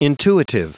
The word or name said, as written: intuitive